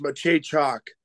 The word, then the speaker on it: Maciejczak: My first question for you is, how did I do at pronouncing your name? – You did almost perfect. It's Maciejczak.